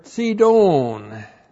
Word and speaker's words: Sidon: And then we have with Tyre, Sidon. T-S long I-D long O-N. Sidon.